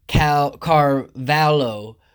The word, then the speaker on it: Carvalho: It's Carlton Carvalho, and that's spelled C-A-R-V-A-L-H-O.